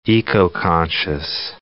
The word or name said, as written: eco-conscious